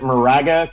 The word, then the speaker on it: Moraga: He is a senior from, I believe we pronounce it Moraga, California. Is that how I say it, Nick? – Yeah, that works. Yep.